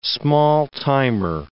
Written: small-timer

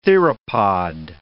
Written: theropod

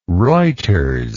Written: reuters

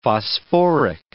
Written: phosphoric

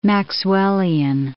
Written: Maxwellian

